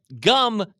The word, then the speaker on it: Jumm: It's spelled J-U-M-M, but Gum kindly lets me know that it's actually pronounced like gum.